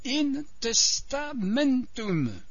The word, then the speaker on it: in testamentum: Mais voici un autre exemple, qui est plus compliqué, in testamentum. L'accent est sur la syllabe men.